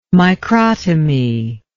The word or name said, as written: microtomy